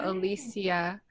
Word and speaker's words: Alicia: The C is pronounced as an S. – Okay. – So Alicia.